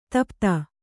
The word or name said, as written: ಧೂಪಿತ